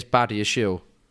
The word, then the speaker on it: Badiashile: But I did ask said person how you pronounce the name, and it is Badiashile. Not Badiashile.